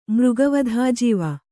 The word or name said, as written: ಮೃಗವಧಾಜೀವ